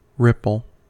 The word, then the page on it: ripple: American English